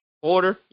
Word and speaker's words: Huerter: What's it, Kevin Huerter? – What, Huerter? – Water, yeah, Kevin Water, yeah, that's the name.